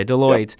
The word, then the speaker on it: Deloitte: There was Barry Salzberg, CEO of, uh, is it Deloitte? Is that how it's pronounced? – Yes. Okay, Deloitte.